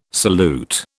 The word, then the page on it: salute: British English